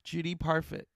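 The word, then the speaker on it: Parfitt: Everyone has come to the consensus that Kathy Bates was robbed for even a nomination for that, as was Judy Parfif. How do you say her name? Judy was also in Girl with the Pearl Earring. Oh, such a great actress. She's still with us. – Judy Parfit.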